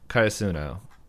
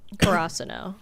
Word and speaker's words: Karasuno: Karasuno.